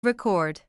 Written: record

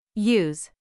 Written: use

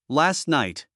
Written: last night

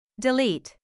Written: delete

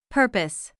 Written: purpose

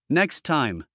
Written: next time

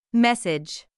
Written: message

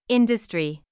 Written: industry

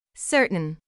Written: certain

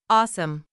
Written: awesome

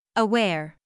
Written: aware

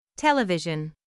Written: television

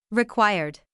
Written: required

